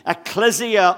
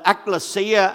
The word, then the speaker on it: ecclesia: You're part of the church is called the ecclesia or the ecclesia, I think is the proper way. Dave keeps correcting me, is the proper way to say it.